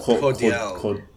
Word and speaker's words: Entente Cordiale: And, you know, this was really enforced by the Entente, how do you pronounce it? Entente Cordiale? – Entente. – I know Entente, but Cordiale. I would say Cordiale. – Yeah. – Cordiale. In 1904.